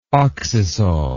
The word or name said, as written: oxisol